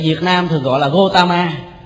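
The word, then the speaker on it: Gautama: Ở Ấn Độ họ phiên âm là Gotam, bởi vì cái chữ A là nguyên âm đặng cuối câu á, thì không có đọc, đọc là Gotam Buddha, đọc là Bút, Gotam Bút.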